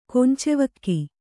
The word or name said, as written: ಕೊಂಚೆವಕ್ಕಿ